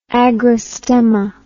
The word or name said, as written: agrostemma